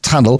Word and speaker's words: Tunl: Got only one vowel in it. T-U-N-L, pronounced Tunnel, focusing entirely on exports from South Africa.